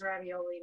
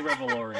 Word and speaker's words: Revolori: I'm going to say his name is Ravioli, and it's not Ravioli. – Tony Revolori, yeah.